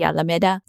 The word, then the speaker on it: Alameda: And being in California, a lot of the city names, for example, Alameda, which is the city I live in, it's pronounced Alameda when it should be Alameda.